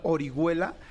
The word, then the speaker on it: Orihuela: Anamar Orihuela, acuérdense que Orihuela se escribe con H, Anamar Orihuela.